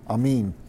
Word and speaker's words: amen: There's no A sound in the ancient language. Actually, in Hebrew, the word is amin.